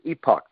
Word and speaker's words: Epoch: The Epoch, I think it's pronounced Epoch Times. Uh, some people say Epic, but it's pronounced it's spelled E P O C H Times.